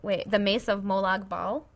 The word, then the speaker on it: Molag Bal: It is the Mace of Molag Bal.